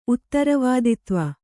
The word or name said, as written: ಉತ್ತರವಾದಿತ್ವ